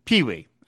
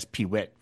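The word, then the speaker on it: Peewit: Pronunciation note, Pee-wee of Johan and Pee-wee is pronounced Pee-wee. I was saying it as Pee-wit.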